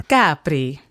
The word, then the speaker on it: Capri: He spoke Italian, and that's the way they pronounce it there. – Capri.